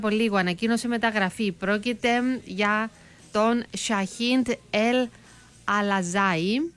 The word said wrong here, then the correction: Αλάτσι: ή τον λέω λάθος, Αλάτσι, μάλιστα. Σαχίντ Ελ Αλάτσι, αυτό είναι ακριβώς όπως προφέρεται το όνομα του.